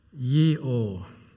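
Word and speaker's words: Yirow (יראה): In the Old Testament, the main word for fear is Yirow, Y-I-R-A-W, that's how it's pronounced.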